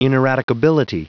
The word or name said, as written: ineradicability